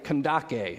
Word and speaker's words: Kandake: He is a court official of Candace. In the Greek, it's like Kandake is how it's actually pronounced.